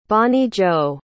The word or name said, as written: Bonnie-Jo